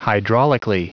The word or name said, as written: hydraulically